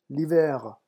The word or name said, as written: l'hiver